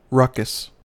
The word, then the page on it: ruckus: American English